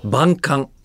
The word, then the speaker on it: 万感: 万感え 1 万 2 万の万万と書いて感じると書いて万感、